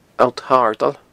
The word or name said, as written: Allt Shardail